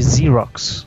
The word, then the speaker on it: Xerox: Na verdade, eles não pronunciam o X como a gente pronuncia, né? Eles chamam de Xerox, como se fosse um Z.